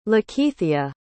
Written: Lakeithia

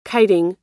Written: kiting

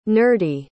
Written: nerdy

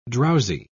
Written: drowsy

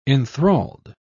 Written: enthralled

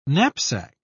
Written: knapsack

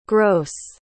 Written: gros

